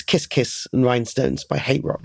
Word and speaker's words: Hate Rock: If you've read the band name, it's the HTRK, but it's pronounced Hate Rock.